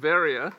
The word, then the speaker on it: Veria: It's a town in Greece called Veria. It's just a difference of the way it's become pronounced over time.